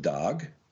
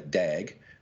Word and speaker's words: Darg: Now, I pronounce it Darg, like the Swedish or the Scandinavian version, because in Australia, if I was to say Dag, that's an idiot.